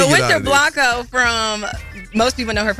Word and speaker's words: Winter Blanco: And in this situation with Winter Blanco, am I saying her name right? – Yes. – Blanco? Okay, I just want to make sure.